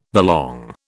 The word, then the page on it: belong: British English